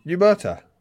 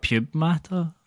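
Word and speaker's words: Pneumata: We've discussed the silent P before. – Piumata. That's what I said. – There we go. – I'm very bad. – You said pube matter.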